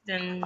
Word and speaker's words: Pristine: Pristine got it. Is that how you say it? Pristine? – Yes. Pristine.